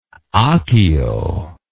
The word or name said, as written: ahkio